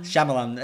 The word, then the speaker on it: Shyamalan: I probably do know how to say his name, right? – M. Night Shyamalan. – Shyamalan.